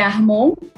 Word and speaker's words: Armond: Meu sobrenome é Armon, essa é a pronúncia, mas está tudo certo, a gente atende por qualquer forma.